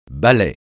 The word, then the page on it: ballet: francais